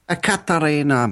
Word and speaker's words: Ekaterina: Akaterina Makarova. The more beers I have, we should get these stories out of the way early. Ekaterina Makarova. There you go.